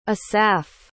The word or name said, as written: asaph